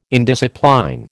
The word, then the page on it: indiscipline: American English